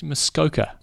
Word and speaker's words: Muskoka: Okay, other news, Ironman Mosquito. – Mosquito, well, good pronunciation. Actually, Muskoka. – Muskoka, okay. – Muskoka.